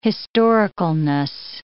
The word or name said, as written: historicalness